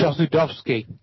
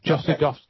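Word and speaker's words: Chossudovsky: You want to pronounce that for me again? – Chosudovsky. Chosudovsky is the name. – Chosudovsky, right.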